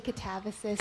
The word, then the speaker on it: Katabasis: I think you can say Katabasis, but I really don't mind how you pronounce it.